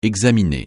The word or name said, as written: examiner